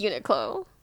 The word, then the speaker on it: Uniqlo: It is Uniqlo.